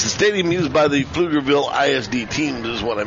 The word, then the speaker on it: Pflugerville: It's actually, it's a P and then the word field, but the P is silent, similar to the name Pflugerville, which is how they named the facility here.